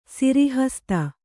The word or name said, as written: ಸಿರಿಹಸ್ತ